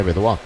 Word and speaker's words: Bidois: Una recta 98 millas que tiene Vidua. B-I-D-O-I-S. La pronunciación correcta, francés.